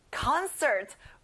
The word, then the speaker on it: concert: 자, con에 강세를 두고요. 끝에 r 발음까지 정확하게 해보세요. Concert.